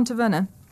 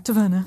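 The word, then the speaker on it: Tavener: So John Taverner, or Taverner, I don't know how you say his name. – I preferred the first one. – Yeah, Taverner.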